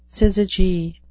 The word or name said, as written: syzygy